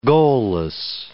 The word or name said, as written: goalless